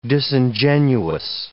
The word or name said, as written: disingenuous